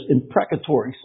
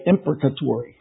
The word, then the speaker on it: imprecatory: There are in the Psalms what are known as imprecatory Psalms, or some people say imprecatory Psalms. Depends on where you put the accent on the right syllable.